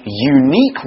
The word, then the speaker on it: unique: It's spelt U-N-I-Q-U-E, okay? Unique. It's got that K sound, which we find with a Q-U-E in English.